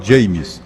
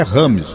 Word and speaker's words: James: quando foi na hora da entrevista, né, no centro de imprensa da Copa do Mundo, ele disse: o meu nome não é James. A pronúncia do meu nome é Rames Rodrigues.